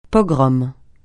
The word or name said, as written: pogrom